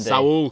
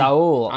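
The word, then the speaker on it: Saúl: Yeah, so wrote about Saul Niguez this week, although, sorry, I'm pronouncing it wrong, aren't I? – Saul, come on. – Saul.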